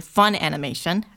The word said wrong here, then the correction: Funimation: I keep saying it wrong for some bizarre reasoning, but... – Oh yeah, Funimation, I've heard different things. I'm totally wrong.